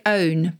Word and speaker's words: pwn: So famously, there's a word which is pronounced like own, but it's spelled P-W-N. And it's used in the same sense as own. So to own an opponent, P-W-N, an opponent, is to completely dominate them in online play.